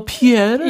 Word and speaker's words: Pierre: Oh, Pierre. Yeah. A very good pronunciation.